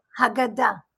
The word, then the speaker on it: Haggadah: And how many times have I read through, how did you say it? Haggadah? – Haggadah. – Haggadah. Okay. I was close in my pronunciation.